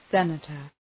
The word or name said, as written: senator